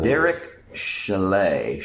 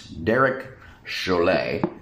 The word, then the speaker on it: Chollet: Derek Chalet. – Like the ski cabin, or? – No, that would be a C-H-A-L-E-T. This is C-H-O-L-L-E-T. So, officially, the counselor of the department, Derek Chalet.